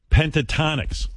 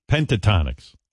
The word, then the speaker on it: Pentatonix: Pentatonix. – Correct. – Pentatonix.